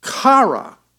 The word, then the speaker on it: chara: The word in Greek for joy that's used here is chara, C-H-A-R-A-H is the pronunciation.